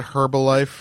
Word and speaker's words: Herbalife: You just call it Herbalife. – Yeah. – You just pronounce the H. – Yeah. – Like you're a fucking Brit.